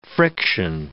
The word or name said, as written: friction